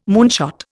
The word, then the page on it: moonshot: Indian